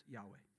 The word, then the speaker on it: Yahweh: So we don't know exactly how it's pronounced, but our best guess is that it's pronounced Yahweh. Sure, that sounds good.